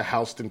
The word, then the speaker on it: Houston: when they pronounce it Houston County.